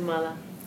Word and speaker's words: mala: What's a mala? – Necklace. – Yeah. – Stains. – It's garland. Okay, that's mala. – Oh. – Stain or impurity is mala. It's a short A.